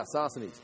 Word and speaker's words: Sosthenes: Chapter 1, you can see when chapter 1, verse 1, it says, as we look at this last one, make sure I say that right. Yeah, Sosthenes.